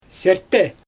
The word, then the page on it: sʲɛrtɛ: Xyzyl